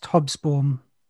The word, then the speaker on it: Hobsbawm: It's called, actually, I think you'll find it's pronounced Hobsbawm, George.